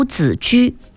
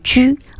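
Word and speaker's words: 子车: 赫连黄府，尉迟公羊，澹台公冶，宗正濮阳，淳于单于，太叔申屠，公孙仲孙，轩辕令狐，钟离宇文，长孙慕容，鲜于吕丘，司徒司空，齐官司寇，长都子居。这个子居两个字哈，一个是孩子的子，一个是车那个字的另一个读音居，我们象棋当中居那个字啊，子居。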